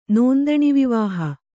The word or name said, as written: ನೋಂದಣಿವಿವಾಹ